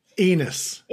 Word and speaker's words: Enes: How do I say it again? – Enes Kanter. Enes Kanter-Freedom. But Enes, yeah. Kanter.